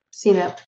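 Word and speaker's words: CNAPP: Is that CNAP? Is that how we pronounce that acronym? – Yep. CNAP.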